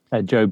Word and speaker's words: Joe: Joe Bains on Facebook. Just Joe Bains everywhere.